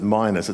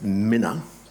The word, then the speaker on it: minas: In Luke 19, it's the parable of the miners, M-I-N-A-S, not the guys who dig down in the ground, but the minas. Yes? No? I pronounce it minus. It's probably supposed to be pronounced minna, but I'm too academically lazy to get my tongue around it.